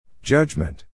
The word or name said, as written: judgement